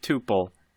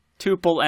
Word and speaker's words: tuple: There are two main pronunciations of tuple. Tuple and tuple. Neither is incorrect.